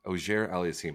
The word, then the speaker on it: Auger-Aliassime: Auger-Aliassime.